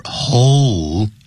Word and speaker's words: hole: You should pay very close attention to the pronunciation of this word, hole, H-O-L-E. Because this word involves this letter, L, so that's why as we read this word, we should say hole.